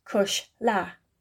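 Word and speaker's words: cuisle: The other word they use is acushla, which is another old word that comes from the Gaelic for darling, which is cushla, pronounced cushla.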